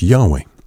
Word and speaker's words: Yahweh: So let's use God's proper name, which is the, uh, the four letters transliterated in English to YHWH and usually pronounced today as Yahweh.